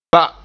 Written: ba

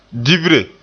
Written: dibre

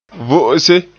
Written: vo'ose

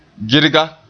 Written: girga

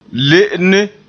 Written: le'ene

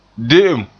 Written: de'em

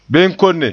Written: benkonne

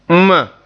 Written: nma

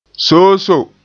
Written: soosoo

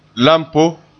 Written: lampo

